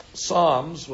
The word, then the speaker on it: Psalms: However, I must tell you that if you open up Tehillim, Psalms with a silent P.